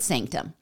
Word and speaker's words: SNCTM: Okay, I didn't know about this series, and Sanctum is spelled in all capital letters, and there's no vowels, so it's just SNCTM, so Naked Sanctum.